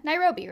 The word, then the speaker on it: Nairobi: He was living in Nairobi, I think that's how it's pronounced. Nairobi, Kenya. Nairobi, right? That's how I've always heard it, yeah.